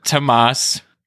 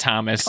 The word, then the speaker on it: Tomas: an islander called Tomas, and it's spelled that way. It's not Thomas.